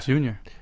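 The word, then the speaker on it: Yunior: Is it Junior or Junior? – Yeah, Junior. – Junior. Well, I've always wondered because the Y is pronounced J in Puerto Rican Spanish.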